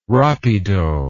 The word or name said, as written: rapido